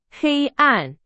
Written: 黑暗

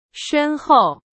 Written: 深厚

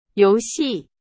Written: 游戏

